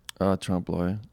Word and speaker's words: trompe-l'oeil: Trompe-l'oeil.